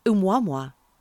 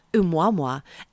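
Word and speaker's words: oumuamua (ʻOumuamua): It's called, you're going to love this, it's called oumuamua. – How do you even know you're saying that properly? – I know I'm saying it properly because I Googled it. Oumuamua. And it's spelled O-U-M-U-A-M-U-A.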